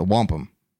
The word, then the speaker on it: wampum: But there is like, we, you know, all jokes aside with the Wanap Belt, is it the Wanap Belt? How do I say it again? – The Wampum.